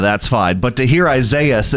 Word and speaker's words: Isaias: So Isaias is the correct pronunciation of it.